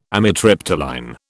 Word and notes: amitriptyline: British English

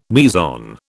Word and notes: meson: British English

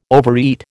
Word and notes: overeat: American English

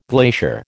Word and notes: glacier: American English

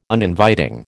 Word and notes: Uninviting: American English

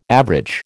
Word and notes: average: American English

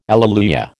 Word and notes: alleluia: American English

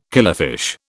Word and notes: killifish: British English

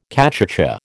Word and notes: cachucha: American English

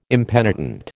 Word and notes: impenitent: American English

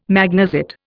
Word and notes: magnesite: Indian

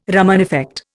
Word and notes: Raman effect: Indian

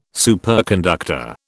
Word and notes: superconductor: British English

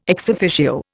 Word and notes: ex officio: Indian

